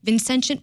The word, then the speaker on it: Vincentian: Yes, so the Vincentian values that DePaul has...